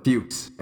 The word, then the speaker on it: Fuchs: Ruth would retire on June 1st of the same season after disputes with, how do you say it again? – Fuchs and the rest of the team. Okay. I guess, yeah, funny that it rhymed.